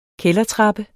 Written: kældertrappe

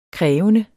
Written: krævende

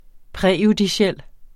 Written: præjudiciel

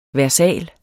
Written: versal

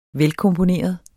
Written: velkomponeret

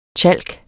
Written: tjalk

